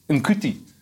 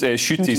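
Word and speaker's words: Ncuti: I would have assumed it was pronounced Nkuti, but apparently it's pronounced Shuti.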